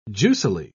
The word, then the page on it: juicily: British English